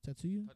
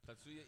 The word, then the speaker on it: Tatsuya: How do you say his first name? Tatsuya? – Tatsuya. Imai. – Is it A at the end? Tatsuya? – Yeah. – Tatsuya Imai.